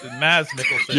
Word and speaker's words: Mads Mikkelsen: Actually, I've learned this week it's pronounced Mass. or like you're eating the D, basically. So it's Mads Mikkelsen.